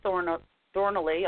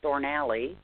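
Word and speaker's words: Thornalley: And this is Phil Thornally, although I think Kazem pronounced it Thornally. So perhaps that is proper pronunciation. He would know better than we.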